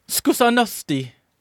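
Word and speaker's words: zkušenosti: Skusenosti. – Yep, there you go. Skusenosti. – You did it well. You just put the emphasis in the wrong spot.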